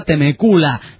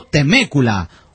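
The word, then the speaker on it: Temecula: La ciudad de donde escribe el Polichafa no se pronuncia Temécula, sino Temécula.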